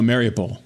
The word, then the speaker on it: Mariupol: I think is in Mariupol. Mariupol. Mari Mario. I can't pronounce these cities. Oh, Mariupol.